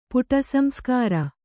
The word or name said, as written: ಪುಟಸಂಸ್ಕಾರ